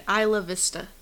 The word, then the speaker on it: Isla Vista: Isla Vista.